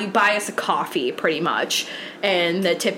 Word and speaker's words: Kofi: I made us a coffee account, which it's like... – Not C-O-F-F-E-E. – It's spelled K-O-F-I, if you guys know what coffee is.